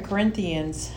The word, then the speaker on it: Corinthians: But anyways, it's 2 Corinthians 10:4-5.